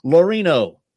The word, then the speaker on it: Laurino: Katie Laurino.